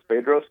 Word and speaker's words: Bedros: It's Bedros Kalilian.